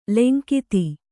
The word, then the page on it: ಲಂಘಿತ: langhita